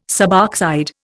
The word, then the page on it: suboxide: Indian